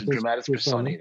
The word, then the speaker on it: dramatis personae: Is that how you pronounce it? – Yes, dramatis personae.